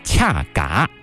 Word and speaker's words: 恰嘎: 然后查了一下官方的这个读音，恰嘎，就是你让我乍看的话，我可能会说恰嘎，对这个恰啊，很多人会说我去恰饭啊，就是吃的意思，很好理解。